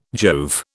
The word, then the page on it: Jove: British English